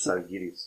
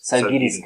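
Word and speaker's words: Žalgiris: weil du hast entweder Barça oder wie spricht man die nochmal aus? Salins. Kaunas. Kaunas, wie, wie heißen die richtig? Salgiris. – Salgiris, Kaunas.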